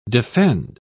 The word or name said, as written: defend